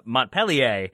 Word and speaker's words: Montpellier: And yet, to uh, hear about the team that Gioacchini plays for and know that it is pronounced Montpellier and know that uh, just north of Richmond there's Montpelier, uh, Montpelier.